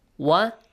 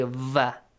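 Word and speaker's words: Wa (و): Make sure you don't say va. Va ja'alu, we don't have. In Persian, you might say va. Yes? – Yes. – In English, you might say v. You might have this sound with the letter V. But in Arabic, you don't have it. Wa.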